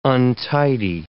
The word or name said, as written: untidy